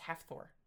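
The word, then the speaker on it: Hafþór: Hafbjor. – That's a P and a B combined. – Yeah, Hafbjor. – Hafthor. I think it's pronounced Hafthor.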